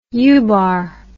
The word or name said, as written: Ubar